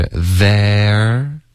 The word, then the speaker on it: there: 大家注意 z 的音，舌尖一定要碰到上齿，OK，导致一个摩擦音，不能用一个 z 的音，OK，所以是 there，然后后面 you 呢，Rachel 就弱读成一个 y 的音，there ya 有一个 y 的音，there you go，是一个双元音，OK，别用一个中式的 go 的这个音代替它，中文的发音就是会比较钝。